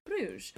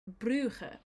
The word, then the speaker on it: Bruges: And we're in the city of Bruges. Or you can also pronounce it Brugge.